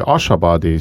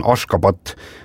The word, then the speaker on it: Ashgabat: Ja selle pealinna tänapäevane õige kirjapilt ja hääldus on Ashgabat ja siis 1991. Aastal nimetati siis paljud linnad ja asulad selles riigis ümber ja kui vanasti tunti seda ikkagi tõepoolest selle Ashgabati nime all, siis Ashgabat on tänaseks see nii-öelda nagu õige hääldus ja kirjapilt, see S-il on mingisugune väike siuke konks all ja Ashgabat täpselt niimoodi ta ongi.